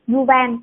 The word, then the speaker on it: Yuval: tác giả non fiction thì mình thích của Yuvan Yuvan Hanu, Haruji không uh, không biết đọc đúng tên của bác không nữa nhưng mà ừ. Yuval ở đúng rồi